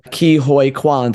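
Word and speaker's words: Ke Huy Quan: Absolutely incredible performance, I think, from Ki Hoi Kwan.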